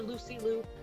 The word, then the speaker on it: Lucy Liu: How is Jackie Chan and Bruce Lee not like the first, or Lucy Liu?